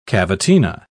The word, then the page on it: cavatina: British English